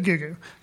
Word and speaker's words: Gugu: Like Gugu. Because that apparently is how you say her name, yes. G-U-G-U.